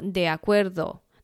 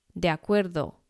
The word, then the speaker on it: de acuerdo: 第三个字呢，应该说它是一个片语啦，它是两个字，它念作 de acuerdo。那当然这个 de 然后紧接着一个母音 a，所以我们要把它连音念作 de acuerdo de acuerdo。